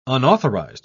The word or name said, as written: unauthorized